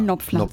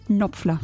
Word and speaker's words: Knopfler: Man spricht es tatsächlich nur Knopfler aus. Ohne K. – Knopfler. Ich weigere mich. – Ja, so. – Mark Knopfler.